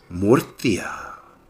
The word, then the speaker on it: Murcia: Murcia.